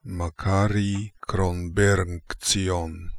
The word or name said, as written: Kronbernkzion Makary